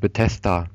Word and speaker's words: Bethesda: Gibt es ja auch schon ganz viele Trailer und Spielszenen am Stand von Bethesda. Wie spricht man die denn korrekt aus? Das weiß ich nie. – Wenn ich das TH nicht richtig aussprechen könnte, Bethesda. Würde ich sagen Bethesda.